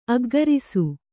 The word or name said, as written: ಅಗ್ಗರಿಸು